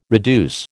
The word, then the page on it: reduce: American English